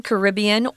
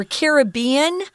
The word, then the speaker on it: Caribbean: Well, in 2004, Hurricane Ivan thrashed the southern Caribbean or Caribbean. You can say that either way, both pronunciations are correct, where Union Island is located.